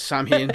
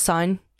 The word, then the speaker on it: Samhain: That might be the correct pronunciation, but I'm going the movie route, so it's Samhain. – I know. They got it wrong in the movie, for sake. – That's the way I pronounced it, too. – So wait, how is it incorrectly? Pronounced? – Son or son. Son. Yeah. – S-O-W-N.